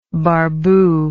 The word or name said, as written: barbu